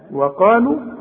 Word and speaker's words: وقالوا: وقالوا اهي حرف علة، ما قولوش برضو، اكتبوا وما انطقوش.